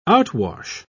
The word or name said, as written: outwash